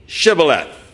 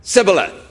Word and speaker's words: Shibboleth: And if they said no, then they said unto him, "Say Shibboleth." And the fellows would answer, "Sibboleth." For they could not frame the word to pronounce it right.